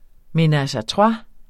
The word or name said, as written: menage